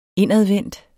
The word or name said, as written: indadvendt